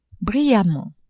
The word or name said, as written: brillamment